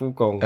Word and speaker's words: Wukong: Danach ging es weiter mit äh, Black Myth, die äh, Wukong mhm gezeigt haben. So, habe ich das richtig gesagt? – Black Myth Wukong. Black Myth ja. Wukong. Das große, Ach so, äh, genau.